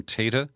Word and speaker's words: Tata: I now believe he pronounces his name Tata.